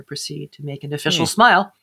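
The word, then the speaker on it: Smial: These Smeal? – Smile. S-M-I-L. – Yes, sorry.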